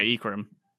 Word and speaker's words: Ikram: So Ikram, is that how you say his name? – Yeah, Ikram.